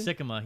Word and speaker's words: Sikkema: Sikkema, yep. – Look at that, like Hickama.